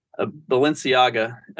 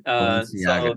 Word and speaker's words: Balenciaga: Balenciaga. Balenciaga.